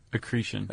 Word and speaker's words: accretion: accretion? – Accretion.